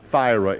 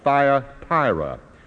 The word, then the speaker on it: Thyatira: It rhymes, but it is not Thyra. It is Thya, T-H-Y-A, Thyatira.